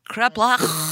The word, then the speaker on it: kreplach: Kreplach. Are those the potato pancakes? Am I saying it right? – Please just say it again. – Kreplach. – Yeah.